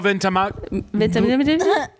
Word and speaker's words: Ventimiglia: Milo Ventimiglia.